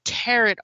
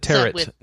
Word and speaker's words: tarot: This is from Japan-based developer Cherry Moji Game Studio, and it is built as an occult and tarot RPG. – You said tarot. Did you mean tarot by any chance? – Sorry, I pronounced that wrong.